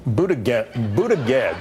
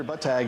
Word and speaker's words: Buttigieg: Anche in America c'è questo problema di come pronunciare Pete Buttigieg.